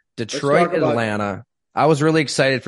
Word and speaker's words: Detroit: Detroit, the emphasis on the first syllable.